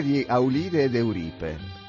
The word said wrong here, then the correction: Euripide: Euripide. – Eh, Euripide.